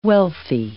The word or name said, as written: wealthy